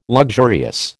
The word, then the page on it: luxurious: American English